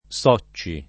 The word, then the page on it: Socci: lingua italiana